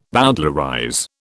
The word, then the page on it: bowdlerize: British English